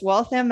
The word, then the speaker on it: Waltham: Lives in Waltham, Massachusetts. And so once again, welcome to the show. – Thank you so much. – Did I pronounce Waltham correctly? – Yes, Waltham, Massachusetts.